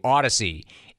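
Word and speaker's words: Audacy: Odyssey, A-U-D-A-C-Y, A-U-D-A-C-Y.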